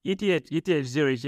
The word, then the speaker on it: ETH Zurich: ETH Zurich, yes.